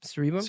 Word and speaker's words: cerebrum: Is that how you say it? – Yeah. – Cerebrum? Cerebrum? – Cerebrum.